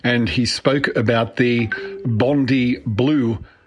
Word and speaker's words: Bondi: It's a big beach in Sydney, and you pronounce it Bondi, as in Bond-EYE, even though it is spelled Bondi, Bondi.